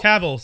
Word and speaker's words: Cavill: Cavill, sorry.